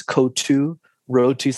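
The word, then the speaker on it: kottu roti: So I guess the English pronunciation is kotu roti. So that's K-O-T-T-U space R-O-T-I.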